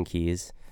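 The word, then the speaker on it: Keys: It's just my name, Austin Keyes. It's K-E-Y-S, not K-E-Y-E-S, like most people spell it.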